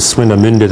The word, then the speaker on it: Swinemünde: And this is actually the German pronunciation is Swinemünde.